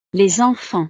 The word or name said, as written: les enfants